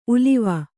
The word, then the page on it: ಉಲಿವು: Kannada